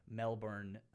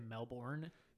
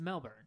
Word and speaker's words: Melbourne: They are a Melbourne indie band. They're like all... – Thank you for saying that right. So many people say Melbourne. – Sorry. – In our area? – Yeah. – It's Melbourne.